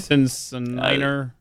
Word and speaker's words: syncytial: Syncytial? Is that how you say it? – Syncytial.